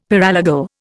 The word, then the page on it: paralegal: Indian